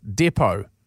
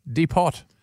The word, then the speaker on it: depot: Depot. You know, where's the T? Depot.